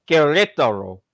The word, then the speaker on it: Querétaro: Querétaro.